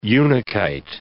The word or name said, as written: unakite